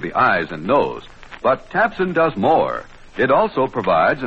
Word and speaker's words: Tabcin: If you have either of these unpleasant ailments, remember, your misery can be relieved quickly and effectively with Tabsin. That's spelled T-A-B-C-I-N, Tabsin. The bright red tablets in the bright red package at all drugstores.